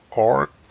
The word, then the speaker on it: ORCID: Down on this page is the links providing your ORCID ID. – ORCID spelt like ID. The flower, ORCID. Pronounced like the flower, spelt without the H.